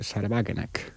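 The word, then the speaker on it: Sahra Wagenknecht: por cierto, también putinista, es el de Sarabagenek, o como claro. se diga en alemán, ¿no? – Está bien pronunciado, ¿eh? – Sí. Bueno, pensé que me ha salido demasiado sajón, pero vale.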